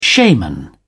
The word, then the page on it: shaman: British English